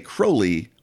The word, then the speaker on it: Crowley: But my friends say Crowley for they know I am holy.